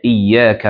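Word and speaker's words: إياك: طيب يبقى شدت اياك تاخد بالك منها، انا بنطقها غلط دلوقتي، هي اياك نعبد واياك نستعين.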